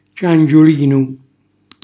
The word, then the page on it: ciangiulinu: calabrese